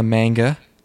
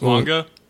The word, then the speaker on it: manga: Okay, this is based on a manga. And it's directed... – It's pronounced manga.